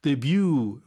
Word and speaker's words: debut: Debut, 보통 T가 발음이 안 되죠.